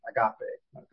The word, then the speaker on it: agape: And I'm using the Southern pronunciation, agape, okay?